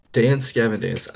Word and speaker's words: Dance Gavin Dance: What uh, did I just say that right? I said Dance Davin Dance. I think. Did you? I think so. Hmm, I knew what you wow. meant. Dance Gavin Dance.